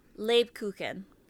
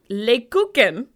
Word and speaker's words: Lebkuchen: Is that how you pronounce that? – Lebkuchen. – Lebkuchen.